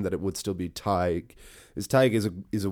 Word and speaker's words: Teigue: And it is this omniscience, to use that word again, of Tig. Tig of the Lee is the original name of this story. It's spelled a very unusual way. It's spelled T-E-I-G-U-E. Now, there are many different spellings for Tig. There is the more traditional T-A-D-H-G, but it has been spelled every way from T-A-I-G to T-E-A-G-U-E, Tig.